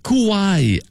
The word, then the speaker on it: Kauai: Justine de Bromont, Kauai, à Hawaï.